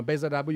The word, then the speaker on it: Behzad Dabu: Uh, Behzad Dabu.